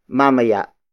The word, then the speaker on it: mamaya: And then the next word is mamaya. Mamaya. Mamaya. Mamaya is spelled as M-A-M-A-Y-A.